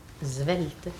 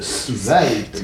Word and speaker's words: svelte: Svelte. – Oui, celui-là. Bon, prononciation correcte, svelte, n'est-ce pas? – Svelte, oui, svelte.